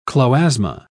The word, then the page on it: chloasma: British English